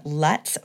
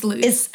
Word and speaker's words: Lutz: Makes her home in Lutz, Florida. Did I say that right? – It's Lutz. – It's Lutz. I knew I messed that up.